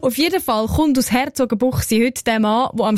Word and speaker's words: Herzogenbuchsee: Herzogenbuchsee gibt ja so einen Ort. Sagt man selbstverständlich korrekterweise Herzogenbuchsee.